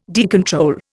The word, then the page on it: decontrol: Indian